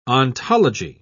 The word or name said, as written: ontology